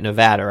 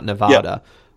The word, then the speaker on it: Nevada: I know I pronounced it right, Nevada, right? It's not Nevada.